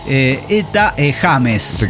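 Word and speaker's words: Etta James: Cada uno viene y dice Ida James. Ajá. Y está buenísimo si alguno se quiere acercar a esa cantante. Por ejemplo, se escribe Eta con doble T. – Ajá. – Eta James. Un saludo para el Jamesito de James.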